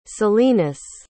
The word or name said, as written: salinas